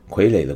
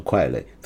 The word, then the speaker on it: 傀儡: 傀儡的国语发音，我是个广州人，所以听到道长说“ 快垒” 特别有亲切感。